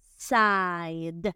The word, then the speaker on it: side: Side. Que suene la D final. Side.